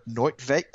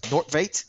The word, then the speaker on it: Nordtveit: Uh, so Tony Martinez, uh, Havard Nordtveit, uh, Nordtveit, there we go, yeah.